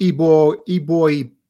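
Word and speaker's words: Eboigbe: Justin, and this one I'm going to have trouble with, Ibo Jibe. – It's actually pronounced Ibo B. – Okay. So the G is silent.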